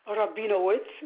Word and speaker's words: Rabinowitz: Rabinowitz, that's spelled R-A-B-I-N-O-W-I, T as in Tom, Z as in Zebra.